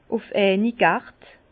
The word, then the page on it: ùf eini Gert: Bas-Rhin; Herrlisheim